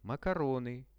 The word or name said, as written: makarony